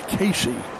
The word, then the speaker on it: Caissie: At the bat, this is Owen Casey, C-A-I-S-S-I-E, pronounced Casey.